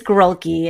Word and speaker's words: Grulke: I know it's Grulke.